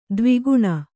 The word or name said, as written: ದವಾಖಾನೆ